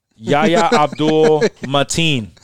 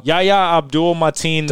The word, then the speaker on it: Yahya Abdul-Mateen: Yeah, pronounce that name. – Yahya Abdul-Mateen. – There you go. – Yahya Abdul-Mateen the second.